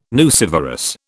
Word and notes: nucivorous: British English

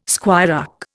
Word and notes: squirearch: Indian

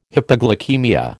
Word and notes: hypoglycaemia: American English

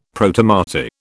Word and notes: Protomartyr: British English

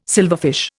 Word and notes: silverfish: Indian

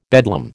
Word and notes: bedlam: American English

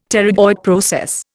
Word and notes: pterygoid process: Indian

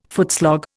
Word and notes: footslog: Indian

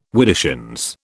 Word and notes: widdershins: British English

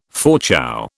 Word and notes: Fauchou: British English

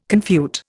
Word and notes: confute: Indian

